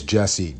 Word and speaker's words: Jesse: My name is Jesse, J-E-S-S-E.